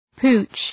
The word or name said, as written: pooch